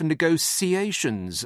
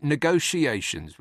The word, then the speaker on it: negotiations: She wants a strong hand for the very tough negotiations, as she pronounces it, over Brexit. I pronounce it negotiations with a lazy SH, not a clipped, bolt upright, scrub clean, sharp S.